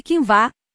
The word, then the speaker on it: quinoa: Embora a quinoa, pronúncia-se quinvá,